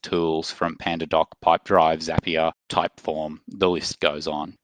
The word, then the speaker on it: Zapier: Although previous to speaking to you, I think I was calling it Zapier. As you pointed out to me, it sounds like happier. So Zapier the correct pronunciation.